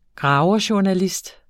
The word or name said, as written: graverjournalist